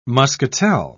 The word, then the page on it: muscatel: British English